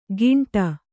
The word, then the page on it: ಗಿಂಟ: kannada